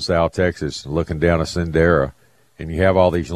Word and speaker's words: Sendera: Yeah, I think it's spelled S-E-N-D-E-R-A, Sendera.